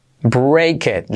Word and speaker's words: break it: Break it. Letra clave R y como E, break it. Y esa T última casi no se dice.